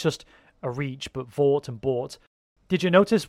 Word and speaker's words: Vought: I did like the fact that Vought rhymes with bought, and it's all about the commercialization of these superheroes.